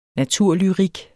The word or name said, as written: naturlyrik